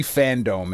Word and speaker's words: Fandome: So they did this DC Fandome, and it was like this 24-hour. – Are you over pronouncing fandom? – No, it is Fandome. Okay. That is how it's spelled.